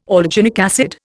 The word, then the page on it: alginic acid: Indian